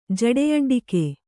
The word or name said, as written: ಜಡೆಯಡ್ಡಿಕೆ